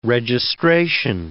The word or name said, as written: registration